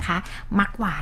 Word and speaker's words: มักหวาน: มักหวานก็จะเป็น MAK นะคะ